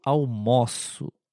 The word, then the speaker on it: almoço: Hoje eu almoço. É um pouquinho mais aberto.